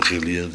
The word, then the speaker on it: Gilead: De G van Gilead. Je maakt er een zachte G van G.